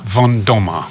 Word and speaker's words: Van Damme: But you are Kira Von Dam, is that right? – So the correct Dutch way to pronounce it would be Kira Van Damme, which sounds a bit different.